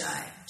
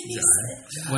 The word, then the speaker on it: Jai: I think it's pronounced Jai. – No, it's Jay. – Jai. – Whatever. I'm he said Jai? – Yeah. – It's his name, so he knows his name.